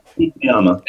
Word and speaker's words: Fukuyama: Fukuyama.